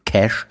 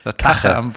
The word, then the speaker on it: Cache: selbst wenn man den Cache, so spricht man das aus, leert. Irgendwie, ich weiß gar nicht, ob es jetzt geht. – Kache am Computer.